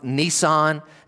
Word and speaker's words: Nisan: So that is in the month, if I'm saying this correctly, in the Jewish calendar at that time, the month Nisan. And am I saying that word correctly? Good.